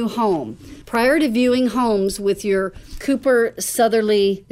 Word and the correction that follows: Sotheby's: am I saying that right? – Sotheby's International Realty Agent, it's important to clarify, these are three big things that I would have conversations with you about.